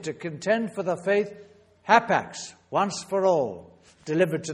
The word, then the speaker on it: hapax: one word. H-A-P-A-X. That's how you would spell it in English spelling. Hapax. Hapax.